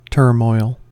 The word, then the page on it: turmoil: American English